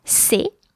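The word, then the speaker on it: ces: The plural form would be C E S. C E S, pronounced C. So these children. Those children will be ses enfants.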